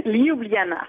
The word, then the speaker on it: Ljubljana: Dites-le comme il faut, là, je ne suis pas sûr. Ljubljana? – Ljubljana. – Ah, Ljubljana. Bon. – Oui, les J se prononcent avec des I finalement.